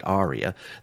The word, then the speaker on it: Aria: I think you may pronounce it Aria, the musical pronunciation, A-R-I-A, and it's Watching the Waves at number 36.